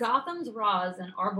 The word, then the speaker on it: Ra's al Ghul: They have a Ra's al Ghul who everyone has said, I'm sorry, Ra's is the correct pronunciation, but, they have a Ra's al Ghul, who is just everyone has been saying how spectacular he is.